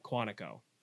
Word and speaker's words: Quantico: And this was the first time ever that the FBI let any movie set or movie studio or Hollywood studio film at Quantico.